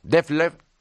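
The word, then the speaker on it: Dethleffs: El fabricante alemán Defleff, lo pronuncio en alemán, lo mismo que el inglés,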